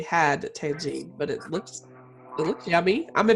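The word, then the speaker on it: tagine: And essentially what your diet consists of is a place called Tangine. – Yeah. – I think it's, is that right? Am I saying that right? – Yeah.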